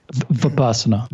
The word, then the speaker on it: Vipassana: And the yoga instructor was like, he was like, I'm getting ready to go do Vipassana, right? Is that how, is that how you say it? – Vipassana.